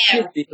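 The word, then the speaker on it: Samhain: S A M H A I N. I think it's pronounced Shivri, believe it or not.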